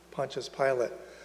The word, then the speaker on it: Pontius Pilate: If you pronounce his name in Latin, it would be Pontius Pilate. So when you say Pilates, you're close, but that is not Pontius Pilate.